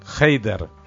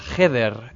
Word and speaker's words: Heider: Heider. – Aunque en hebreo la palabra heder es habitación, en este caso y en su pronunciación yiddish se refiere a la escuela elemental que generalmente ocupaba en el shtetl una única sala.